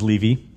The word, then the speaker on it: Levy: My last name is Levy. – That's what I thought. – Everybody pronounces it Levy. It's, yeah. – It's spelled L-E-V-Y.